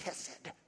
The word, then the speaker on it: hesed: And if you're a Jewish person, you say it like hesed, right? But I can't get that part very well, so I just say hesed, hesed.